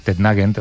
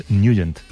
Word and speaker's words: Nugent: siempre lo hemos llamado en el barrio Tednagent o Tednagent. – Claro, yo era de Tednagent. – Pero la pronunciación correcta es Nugent. – Te fuiste a Washington y descubriste que era Nugent.